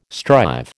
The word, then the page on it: strive: American English